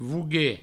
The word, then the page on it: voguer: vougue